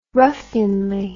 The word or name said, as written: ruffianly